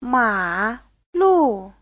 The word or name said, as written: ma3lu4